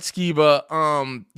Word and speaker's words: Skiba: Skiba, right? – Matt Skiba.